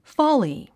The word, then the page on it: folly: fάli; フォーリィ